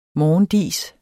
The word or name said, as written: morgendis